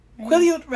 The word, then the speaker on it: Quileute: Quileute Reservation.